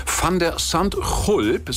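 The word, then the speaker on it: Van de Zandschulp: Van der Sandhulp,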